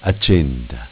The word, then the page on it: Accenda: Dialetto